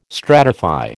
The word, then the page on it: stratify: American English